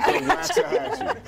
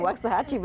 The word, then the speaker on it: Waxahachie: Waxahachie, it's almost like if you said Snacksahatchie. – Yeah. – Waxahachie. I was like, Tina, I want to be honest, tell him Waxahachie, but I don't know if I can pronounce it. – I think you got it.